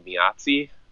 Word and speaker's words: Meazzi: So I don't know how to pronounce it because it's an Italian word, but it looks like Miazzi, I think.